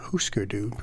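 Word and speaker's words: Hüsker Dü: Husker Du, probably, to give the correct pronunciation.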